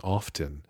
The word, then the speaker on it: often: If you could see me, my body language indicated that that's not how I really pronounce that word, nor do I pronounce the T in often, uh, and you shouldn't either.